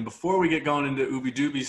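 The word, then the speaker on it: Ubbi Dubbi: My friends in Florida would like to disagree, but Disco Donnie literally put out a video saying it's Ubi Dubi.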